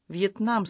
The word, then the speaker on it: Вьетнам: «Вьетнам», — сказал он. И это короткое слово с ударением на последнем слоге прозвучало тяжко и грозно.